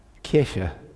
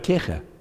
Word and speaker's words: Kirche: It's because the German word which was used for church is the word, how do you pronounce that word? Kirche. Okay, say it loud. You got it, Kirche.